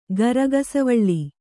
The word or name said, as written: ಗರಗಸವಳ್ಳಿ